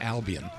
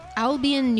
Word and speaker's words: Albion: Albion. – Albion, New York, doing Warning.